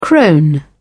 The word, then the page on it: crone: British English